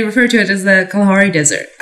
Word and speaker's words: Kalahari: Did you know the Kalahari is actually just like the easier pronunciation that like white folks came up with? It's actually Kalahari.